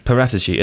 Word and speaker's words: Paratici: Paratici.